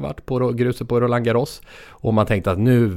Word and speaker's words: Roland Garros: Du kör lite, man märker ditt Counter-Strike-uttal där. Jag tror fransmännen själva säger Roland Garros. – Roland Garros.